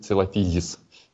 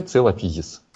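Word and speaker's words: Coelophysis: Но если говорить уж совсем честно, то правильно говорить целофизис. Я вот сам еще до конца не переучился говорить его правильно, поэтому периодически меня сбивает мысль, когда увлекаюсь. Но вообще целофизис. И именно с таким ударением тут никаких сомнений быть не может. Ну и именно с таким окончанием.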